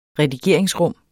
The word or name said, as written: redigeringsrum